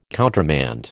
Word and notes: countermand: American English

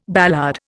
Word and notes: ballade: Indian

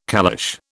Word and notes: caliche: British English

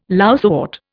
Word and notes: lousewort: Indian

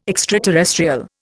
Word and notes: extraterrestrial: Indian

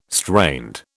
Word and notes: strained: British English

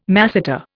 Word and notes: masseter: Indian

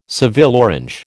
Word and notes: Seville orange: American English

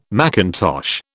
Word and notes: mackintosh: British English

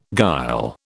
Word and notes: guile: British English

